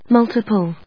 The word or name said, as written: multiple